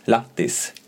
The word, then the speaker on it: Lahtis: Jag fick bannor av mitt uttal för, av la, Latti. La, av eh, min bror som har en eh, fru som är från fin, Finland. – Ja. – Eh, vi redde också ut att det är Lahtis på svenska men jag vet inte exakta alltså, uttalet än.